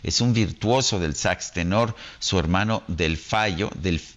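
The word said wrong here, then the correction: Delfeayo: del fallo Ah, me dice aquí se pronuncia Delfío, yo pensaba que era del fallo, en fin, Fío, un gran trombonista, y su hermano Jason, un gran baterista, todos de jazz.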